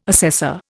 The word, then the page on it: assessor: Indian